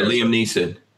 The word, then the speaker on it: Liam Neeson: Liam Neeson. Ain't that how you say his name? – Oh, yeah. – That guy's a leftist big time. Fuck yeah, Liam Neeson.